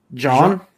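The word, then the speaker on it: Jean: John? – John? – Yeah. – John? – It's split. It is split. Because in the anime, they say John.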